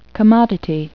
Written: commodity

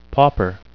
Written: pauper

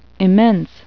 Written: immense

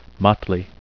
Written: motley

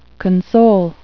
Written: console

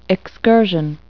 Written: excursion

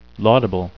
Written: laudable

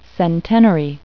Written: centenary

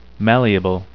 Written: malleable

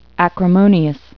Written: acrimonious

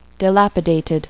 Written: dilapidated

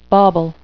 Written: bauble

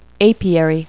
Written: apiary

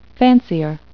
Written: fancier

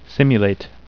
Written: simulate

